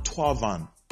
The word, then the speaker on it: Thauvin: It's pronounced Florent Thauvin, not Florent Thauvin. The way it is spelled, it's not pronounced the way it is spelled. It's not Thauvin.